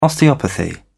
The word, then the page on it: osteopathy: British English